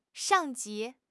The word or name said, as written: 上级